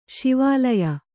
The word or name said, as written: ಶಿವಾಲಯ